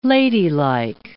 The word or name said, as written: ladylike